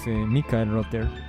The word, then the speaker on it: Michael Rother: No, Michael es un nombre, la pronunciación anglosajona es Michael Rother, que tocó con Kraftwerk, con Can, Harmonia, Cluster, Noi y también con Iplank.